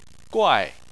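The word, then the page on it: guai4: tone 4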